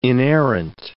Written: inerrant

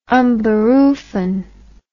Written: unberufen

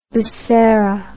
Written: busera

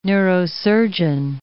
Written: neurosurgeon

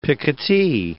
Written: picotee

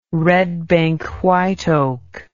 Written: red bank white oak

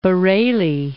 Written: Bareli

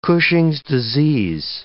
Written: Cushing's disease

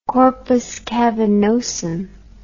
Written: corpus cavernosum

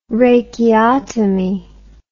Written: rachiotomy

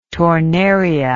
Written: tornaria

